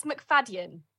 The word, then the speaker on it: McFadden: It's McFadden. – No way. – Like, say it. Yeah, you say it how you say it how you see it.